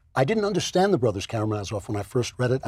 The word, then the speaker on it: Karamazov: Am I saying that right? – Yeah, the Karamazov. – Yes.